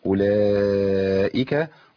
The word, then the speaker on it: أولئك: اولئك خطأ برضو بيقع فيه كتير من اخواننا، هو اثبات الضمة المثبتة في الرسم لكنها محذوفة في النطق، اللي هي بتاعت اللي بعد الهمزة، اولئك ده خطأ، هي أولاء، أولاء همزة مضمومة بعدها لام على طول، الواو اللي انت شايفها ديت اللي عليها دايرة فوقيها دايرة مقفولة كده، دي الواو دي لا تنطق لا وصلا ولا وقفا، ملهاش عندي ذكر إلا في الرسم بس، لكن في القراءة هي تسقط تماما.